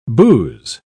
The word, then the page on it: bouse: British English